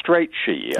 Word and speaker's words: Strachey: All right, you have been reading Young Bloomsbury by Nino Strachey or Strachey, I'm not sure of the pronunciation. – I looked this up, actually, Catherine, and I think it's Strachey.